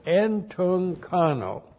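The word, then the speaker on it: Entunkano (ἐντυγχάνω): E-N-T-U, two gammas together. The first one's the transliterator is N, then C-H-A-N, long O. Entunkano.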